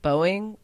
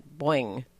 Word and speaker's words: Boeing: Actually, I thought it was funny because I pronounce it Boeing, like hard O-ing, and they all pronounce, all the people that work at Boeing or formerly did, pronounce it like Boing. Boing.